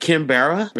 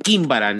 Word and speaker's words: Quimbara: Get a gringo to say Quimbara. Just like Kimbara. You just said. And it'd be like Kimbara. Have them read it. Don't even tell them how to pronounce it. He'd be like, Kimbara, right? No. Quimbara, nigga.